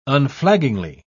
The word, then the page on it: unflaggingly: British English